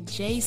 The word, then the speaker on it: Jaie: And that Jaie is spelled J-A-I-E.